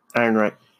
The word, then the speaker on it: Ehrenreich: Ehrenreich.